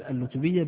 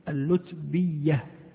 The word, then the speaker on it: اللتبية: يقول اللتبية بضم اللام اللو وإسكان التاء اللت والبثنة الفوقية وكسر الباء اللتبي وتشديد الياء اللتبية. إذن هكذا تنطق حسب الضبط الموجود.